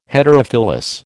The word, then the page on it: Heterophyllous: American English